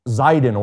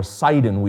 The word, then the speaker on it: Sidon: If you know a little bit about the geography of the area of Israel, Tyre was a neighboring city to another city called Zidon, or Sidon, we often pronounce it.